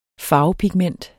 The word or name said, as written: farvepigment